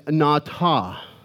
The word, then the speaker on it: nata: So the Hebrew word for planted is nata. I'm going to teach you some Hebrew here. You ready? Say nata. It's spelled N-A-T-A. It's real simple.